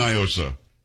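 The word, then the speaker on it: NIOSA: Do not, because it's definitely Nyosa. It is not a Spanish word. What does Nyosa mean in Spanish? Nothing. – I don't know. – Well, that's the whole argument. They try to pronounce it like it's Spanish. It's not. It's an acronym. It's, and for those around the country, it's Night in Old San Antonio, and here we've always said Nyosa, and for some reason, like the Joaquin Castros of the world think you've got to pronounce it like it's a Spanish word.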